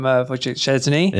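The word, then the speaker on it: Wojciech Szczęsny: It was a wonderful penalty save from Wojciech Szczesny. – Yeah. – I'm pretty sure I've said that right, haven't I? – I think you've done a very good job.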